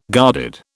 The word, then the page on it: guarded: British English